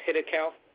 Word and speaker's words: Hiddekel: Now, if you look closely at the, it says, it's pronounced Hiddekel.